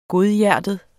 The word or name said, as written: godhjertet